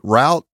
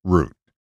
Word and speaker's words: route: No matter how many football announcers and coaches through usage bastardize the word root and call it route, it will always be properly pronounced as root.